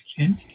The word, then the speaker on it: Kent: My first name is Kent, K-E-N-T. – And your last name? – Davis, D-A-V-I-S.